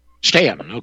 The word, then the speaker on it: Staunton: The story is, and for the audience that doesn't know this, if you want some validity behind these books, he was from how do you pronounce it, Staunton? – Staunton, okay.